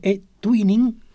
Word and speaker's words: eTwinning: Y también está Salvador Páez Rodríguez, que es el coordinador y embajador del proyecto eTwinning, que creo que lo he dicho bien ahora, ¿no? – Correcto, perfecto.